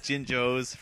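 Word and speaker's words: Jinjo: The jingos. – I believe it's pronounced jinjos for starters.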